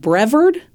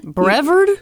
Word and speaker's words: Brevard: Brevard, North Carolina. – Brevard? – You ever heard of this? – Spell it. – B-R-E-V-A-R-D.